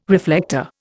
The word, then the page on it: reflector: Indian